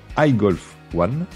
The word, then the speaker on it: iGolf One: Je rappelle que vous êtes enseignant de golf à la Vaucouleur, couleurs dans les Yvelines, et euh, le fondateur de l'application iGolf One, pour le prononcer à l'anglaise correctement.